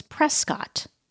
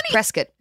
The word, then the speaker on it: Prescott: I always hear it as Prescott because the town in Arizona is, it looks like Prescott, but everyone calls it Prescott.